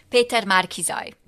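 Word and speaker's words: Péter Márki-Zay: Peter Márkizay.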